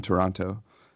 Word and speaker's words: Toronto: Speaking of the Toronto, and I heard you don't pronounce the T. If you're from Canada, you don't pronounce the T in Toronto.